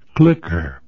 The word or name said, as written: clicker